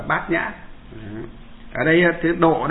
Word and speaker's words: bát nhã: Nhà Phật thì đọc là nhã, bát nhã có nghĩa là trí tuệ, ở ngoài đời họ đọc là ban nhược.